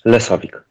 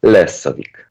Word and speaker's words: Lesovik: Chociaż też yy, Rafał, ale tak naprawdę Lezowik, Lesowik? – Lesowik. Chociaż zagraniczni, yy, e, zagraniczne osoby często pięknie to akcentują albo mówią Lesowik, ale piękniej mówią o tym yy, e, Skandynawowie lessowik. I wtedy się tak rozpuszczam.